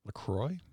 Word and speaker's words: LaCroix: LaCroix.